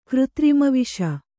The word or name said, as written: ಕೃತ್ರಿಮವಿಷ